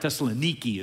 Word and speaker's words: Thessaloniki: Thessaloniki is how they'd pronounce the name of the city.